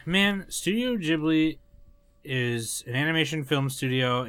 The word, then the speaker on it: Ghibli: It's technically pronounced Ghibli, but everyone calls it something different.